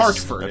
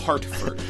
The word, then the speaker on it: Hartford: This is a most emailed story from the Hartford Current. Am I saying that correctly? I've never actually. – Yes. Hartford. Okay. It's Hartford. Yes.